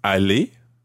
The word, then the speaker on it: aller: A-L-L-E-Z. – Uh-huh. Est-ce que vous allez aller? – A-L-L-E-R. – Good. Okay, they sound exactly the same. Allez, E-Z. Allez, E-R.